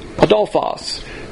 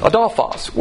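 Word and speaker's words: adelphos: Adolphos. You can pronounce that with an A or an O. I like to do it with an A. Adolphos.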